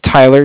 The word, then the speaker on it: Tyler: Yeah, my name is Tyler, T-Y-L-E-R, and I'm calling with Cardiff. It's spelled C-A-R-D-I-F-F, as in Frank Frank.